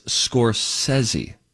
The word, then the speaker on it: Scorsese: I think that's the proper pronunciation. I believe it's Scorsese.